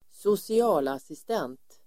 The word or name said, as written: socialassistent